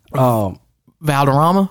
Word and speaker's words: Valderrama: Valderrama? – Yeah, there we go.